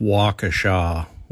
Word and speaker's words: Waukesha: Shannon, it's pronounced Waukesha, Wisconsin.